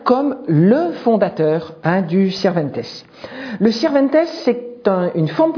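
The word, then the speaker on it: Sirventès: À travers un mode poétique tout à fait particulier qu'on appelle le Sirventes. Ça s'écrit S-I-R-V-E-N-T-E, accent grave, S.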